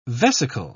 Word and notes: vesical: British English

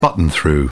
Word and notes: button through: British English

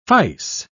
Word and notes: fice: British English

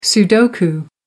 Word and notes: sudoku: British English

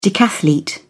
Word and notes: decathlete: British English

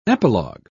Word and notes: epilog: British English

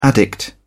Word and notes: addict: British English

